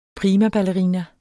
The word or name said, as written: PRIMABALLERINAER